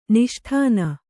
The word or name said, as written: ನಿಷ್ಠಾನ